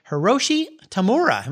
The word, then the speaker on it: Hiroshi Tamura: Hiroshi Tamura. Am I saying his name right? Yes, you are.